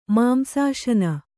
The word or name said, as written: ಮಾಂಸಾಶನ